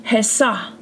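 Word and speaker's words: Hesa (חָסָה): In this verse, the Hebrew word is 26:20, and it's pronounced Hesa, Hesa.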